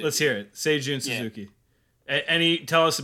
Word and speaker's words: Seijun: Yes, Seijun.